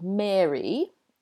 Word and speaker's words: Mary: So we have, in the book, we have Mary, M-A-R-Y, that rhymes with fairy.